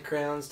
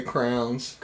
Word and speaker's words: crayon: Do certain crayons taste better than others? – What did you just say? – No, some people say crowns. – Crayon. – See, and they're saying it wrong. – It's crayons.